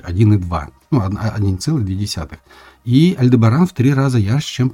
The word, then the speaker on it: Альдебаран: Некоторые поэты писали «пылает красный Альдебаран». Именно так, с ударением на предпоследний слог. Никто не знает точно, как надо правильно произносить название Альдебаран, хотя арабы делали ударение именно на предпоследнем слоге.